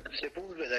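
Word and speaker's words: Sepúlveda: Sepulveda. Se, mi dirai tu qual è la pronuncia giusta perché la sento... – Sepulveda è giusta.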